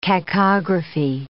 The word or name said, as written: cacography